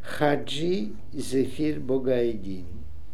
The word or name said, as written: Zephir Bogga Eddin Hadji